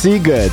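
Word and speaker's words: Sziget: Bo to jest bardzo ważna rzecz, bo pisze się Siget, a wymawia się Siget.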